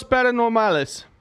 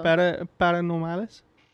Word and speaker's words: paranormales: Sucesos paranormales. Did I say that? – I like that we're getting Hispanic. – Did I say that correctly? – No. – Paranormales? – Paranormales.